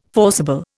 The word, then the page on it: forcible: Indian